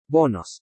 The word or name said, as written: BONOS